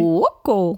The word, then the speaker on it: Cuoco: Big Bang Theory Star Kaylee Cuoco, habe ich das richtig gesagt? Cuoco.